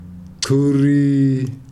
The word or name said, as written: cham curi